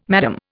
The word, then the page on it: Madame: Indian